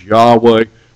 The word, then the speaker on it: Yahweh: We'll not go into the history of all that, but the correct pronunciation is Yahweh, but either word will work.